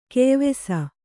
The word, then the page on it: ಕೆಯ್ವೀಸು: keyvīsu